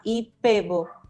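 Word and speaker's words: IPEVO: si lo pronunciamos en español, se llama IPEBO, con B chica.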